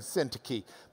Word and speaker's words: Syntyche: Well, if you're from Lubbock, it does indeed look like Euodia and Syntyche. But if you've got the Greek reading next to it, you might as well pronounce it the way they do. So it is Euodia and Syntyche.